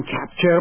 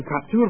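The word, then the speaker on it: Kaptur: یکی از اونهایی که در کنگره به ضرر این لایحه رأی داده، دیروز در یاهو خبر شما میخوندم، عضو کنگره خانم مارسی کپتور، فکر میکنم اینجوری تلفظ بشه، کپتور، فکر نمیکنم کپچر باشه، کپتور باید باشه،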